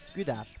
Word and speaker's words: Guder: Gudar.